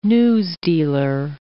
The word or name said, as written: newsdealer